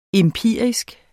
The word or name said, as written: empirisk